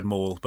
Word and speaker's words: mall: We're going to have to, you're going to have to practice your pronunciation of the word mall before we do the next episode, Luke. – You told me to just say it like Darth Maul.